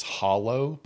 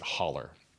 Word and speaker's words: hollow: The actual word is hollow, but we pronounce it holler.